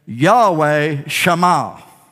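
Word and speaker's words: Yahweh Shammah: To pronounce that is Yahweh Shema.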